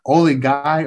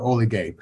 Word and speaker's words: Oligye: No, you can pronounce it two ways. Oli Guy or Oli Gay. O-L-I-G-Y-E.